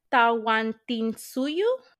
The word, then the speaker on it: Tawantinsuyu: they called it Tawantinsuyu.